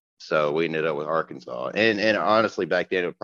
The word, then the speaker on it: Arkansas: I mean, Arkansas sounds better. It kind of rolls off the tongue, but it's weird because you pronounce Kansas, Kansas. – Right. – There's no Kansas when you say Arkansas.